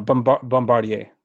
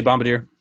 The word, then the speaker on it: Bombardier: We talked about that a couple weeks ago, the pronunciation, remember? – Yeah, I think I got it now. Bombardier. – There you go. – Yeah. You say Bombardier.